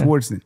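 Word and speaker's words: Swardson: Is that how you say it? – Swartzen? – Yeah. Swartzen.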